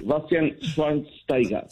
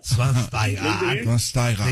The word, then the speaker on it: Schweinsteiger: Después de 37 años de vida, ya sé cómo se pronuncia. Bastian Schweinsteiger. – Schweinsteiger. Sí, muy bien.